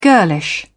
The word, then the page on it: girlish: British English